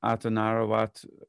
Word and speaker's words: Atanarjuat: Another one, Atanarawat, it's spelled Atanarjuat, which is an Inuit film from up that direction.